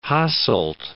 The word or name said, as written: Hasselt